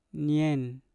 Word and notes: nyen: Mpur

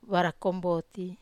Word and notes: vara komboti: Wamesa

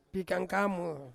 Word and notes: pikangkamo: Ansus

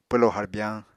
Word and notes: pwlo harbyañ: Apatani

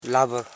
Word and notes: labur: la.bur; Koro

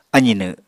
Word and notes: azinw: apatani

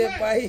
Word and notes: pai: Ansus

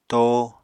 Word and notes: to: Apatani

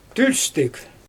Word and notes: tølpstɪg: Xyzyl

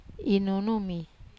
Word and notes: nunu: wamesa

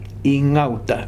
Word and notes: Ingauta: new form of Hgauta

